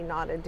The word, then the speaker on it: knotted: They're really, that's this way that character and plot are knotted, K-N-O-T, knotted together, right? – I love that you spell it. – Well, because knot sounds like the negative. – But, like, it's not a verb. – Anyway, I like spelling it. The K is silent. – M-E-2.